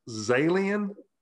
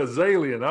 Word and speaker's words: Zalian: Is it pronounced Zalian? – Oh, man, that's good. – Did I get it right the first time? – Yeah. – Okay, good. Yeah. Yeah, Zalian. All right. It's like alien with a Z.